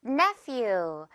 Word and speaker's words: nephew: PH 들어가 있고요. F 발음 나겠죠? Nephew.